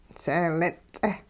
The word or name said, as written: seelin'-ts'eh